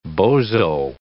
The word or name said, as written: bozo